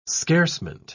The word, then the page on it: scarcement: British English